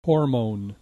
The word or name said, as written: hormone